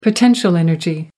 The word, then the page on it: potential energy: American English